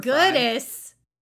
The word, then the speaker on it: Giudice: Giudice went to prison for Giudice. Fraud? Goodness, get out of here with that mess. You better Google that pronunciation before you disrespect my gal Terry again.